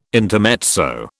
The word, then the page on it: intermezzo: British English